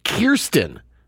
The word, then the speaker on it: Kirsten: During a TikTok appearance, the Spider-Man star revealed that her first name is pronounced Kirsten.